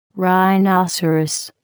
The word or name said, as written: rhinoceros